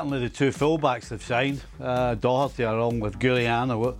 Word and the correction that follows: Reguilón: How do you pronounce it again, Seb? – Reguilón, yeah.